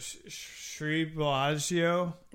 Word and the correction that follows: Sri Balaji: It's Sri Balaji.